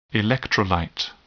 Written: electrolyte

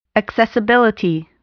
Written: accessibility

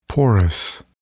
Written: porous

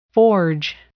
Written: forge

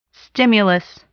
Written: stimulus

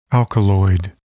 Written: alkaloid

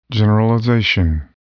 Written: generalization

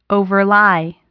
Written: overlie

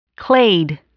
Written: clade

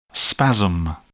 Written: spasm

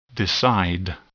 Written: decide

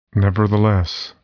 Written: nevertheless

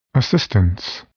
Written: assistance

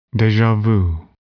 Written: deja vu

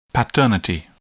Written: paternity

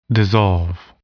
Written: dissolve

